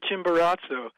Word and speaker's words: Chimborazo: Oh, yes, Mount Chimborazo.